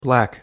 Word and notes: black: English

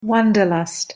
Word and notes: wanderlust: English

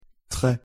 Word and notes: tres: French

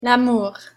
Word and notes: lamour: French

